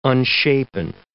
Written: unshapen

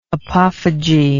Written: apophyge

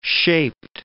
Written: shaped

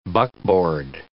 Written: buckboard

